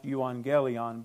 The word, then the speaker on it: euangelion: It's not how it looks. But anyway, it is our English word evangelism, but it's pronounced euangelion.